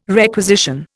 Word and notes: requisition: Indian

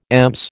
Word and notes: EMS: American English

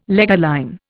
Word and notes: leger line: Indian

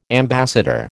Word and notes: ambassador: American English

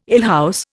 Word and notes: alehouse: Indian

